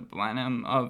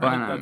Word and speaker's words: Blenheim: Blenheim, that's how you pronounce it, I think.